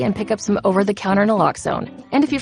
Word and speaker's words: naloxone: For the record, it's Naloxone. Nuh, like, is Naloxone addictive? Nuh. Lox, like Naloxone keeps humans safe, like locks keep bikes safe.